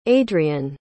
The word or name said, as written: adrianne